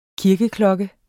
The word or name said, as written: kirkeklokker